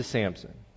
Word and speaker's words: Samson: We moved up to Ehud and Deborah and Gideon and Shamgar and Jair and Jephthah, all this, and then we're back to Samson, okay? And Samson doesn't have a P in it, all right? So P is silent and not there.